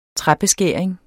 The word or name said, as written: træbeskæring